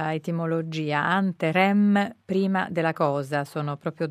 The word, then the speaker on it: Anterem: Noi preferiamo chiamarla anterem, ci abbiamo fatto slittare l'accento tonico. – Ah, giusto, sulla E, sì.